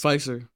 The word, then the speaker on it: Pfizer: Pfizer.